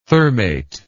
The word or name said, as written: thermate